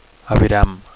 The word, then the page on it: aviram: Catalan